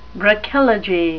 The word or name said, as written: brachylogy